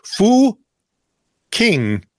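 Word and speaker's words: Fucking: The village is pronounced start with an F. – Yeah. Fu King, but has a rather unfortunate spelling.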